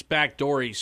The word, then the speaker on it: Baghdadi: Apparently, the president authorized the use of assisted suicide eliminate bagworm Al Baghdadi. – It's backdory, sir. Pronounce it correctly so you sound legit.